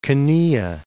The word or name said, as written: Canea